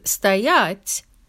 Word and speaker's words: стоять: The difference is stress. With the verb стоит, the stress is on the first syllable, like I just read. But with стоять, it's on the second.